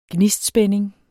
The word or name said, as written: gnistspænding